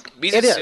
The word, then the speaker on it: Mizutsune: Mizutsune is. is. one of the uh, I, I have fought a, Misusune, a Mizutsune, and um, it's one of the most beautiful monsters in the series.